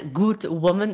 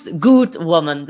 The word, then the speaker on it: Good Woman: Zeg ik dat op de juiste manier? – Absoluut. Yes. Hallo iedereen. Ja, het is inderdaad Good Woman. Ik weet dat veel Belgen het soms verkeerd kunnen uitspreken van Good Woman. Of, maar het is Good Woman.